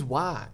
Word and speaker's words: Wark: It sounds like the word is being said wrong every time it's said, but how is it spelled? W-A-R-K? – Yes. – So it is Wark.